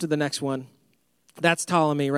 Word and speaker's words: Ptolemy: In fact, the Greek governor of Egypt decided, his name was Ptolemy II. It's spelled with a P. It's a silent P. Don't get weirded out by that.